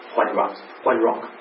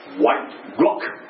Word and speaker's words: White Rock: 因为他越说你住哪里，我说 White Rock、 No，你不应该这样的发音的， White Rock 要两个分开，我们就连在一起了，以后我们一直用这样的代表 White Rock，他就想起来了这样的一个信心。